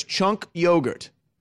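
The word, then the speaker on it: Cenk Uygur: No, it's Chunk. Hold on, say it. It's Chunk Yogurt.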